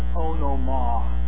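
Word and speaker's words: onomah (ὄνομα): The word name actually is the Greek word onomah. O-N-O-M-A-H. Pronounced that way.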